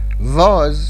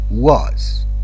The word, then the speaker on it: was: was نه was، was W صدای V نمیده، was where.